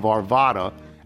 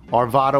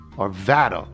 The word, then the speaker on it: Arvada: You gamely tried the soft A of Arvada and the long A of Arvada, but neither is correct. It is simple. Arvada, with the central A being flat, as in the word flat. Arvada.